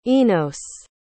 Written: enos

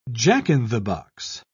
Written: jack-in-the-box

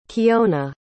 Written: kiona

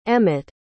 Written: emmitt

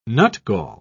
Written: nutgall

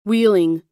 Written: wheeling

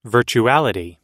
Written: virtuality

